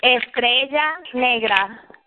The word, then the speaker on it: Estrella Negra: Estrella Negra. – Right, right, right. So I got to roll my tongue.